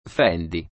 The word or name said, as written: Fendi